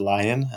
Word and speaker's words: Lyon: You know, my name again is David Lyon, and Lyon is spelled like the animal.